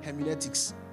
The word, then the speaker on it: hermeneutics: It's called hermeneutics. I got it, Abby. Hermeneutics. Let me spell this for you. H-E-R-M-E-N-E-U-T-I-C-S. H-E-R-M-N...